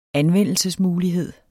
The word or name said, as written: anvendelsesmulighed